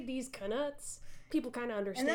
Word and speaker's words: Knuts: D's Knuts. – Yes. – And I like to pronounce the K because that's what Jim Dale does, and also because it differentiates knuts from actual nuts.